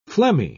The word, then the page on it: phlegmy: British English